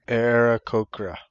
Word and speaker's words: Aarakocra: Aarakocra.